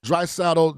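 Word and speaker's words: Draisaitl: Is it Drysdale? – Drysdale didn't seem to be 100%.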